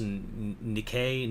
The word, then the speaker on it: Nikkei: but I don't think I think it's pronounced Nikkei newspapers. – Oh yeah, it looks like Nike, but it's not.